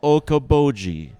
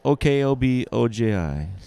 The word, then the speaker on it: Okoboji: If you think Okie dokie is rhymes with Okoboji. – Yeah. – So Okoboji.